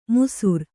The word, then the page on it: ಮುಸುರ್: musur